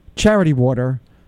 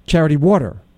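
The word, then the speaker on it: charity: water: Now, you say Charity Water. I say Charity Water. I'm putting the emphasis on water.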